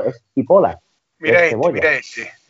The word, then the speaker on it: cipolla: de hecho, en italiano, que se escribe cipolla, ¿vale? Que es la cebolla. Ellos dicen, ellos dicen, ellos dicen cipola, que es la cebolla.